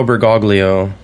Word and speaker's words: Bergoglio: Jorge Mario Bergoglio.